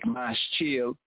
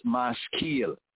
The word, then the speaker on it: Maskil: In the English, it looks like Maschil, but properly pointed and pronounced Maskil from Sakal.